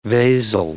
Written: vasal

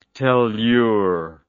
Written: tellur

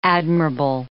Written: admirable

